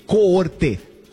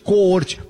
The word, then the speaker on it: Coorte: Olha aqui, galera, a gente fala em Corte 2015, se escreve assim, ó, C-O-O-R-T-E, Coorte, Coorte. Por que Coorte. Isso, porque ela vem de uma palavra em latim.